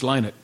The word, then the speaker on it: Lynott: It's Lynott. – Lynott. – As in why not? Scott will say Lynott as in why not. That's okay. So Phil Lynott. – Because someone told me it was Lynott, and I'm like, I've never heard that before.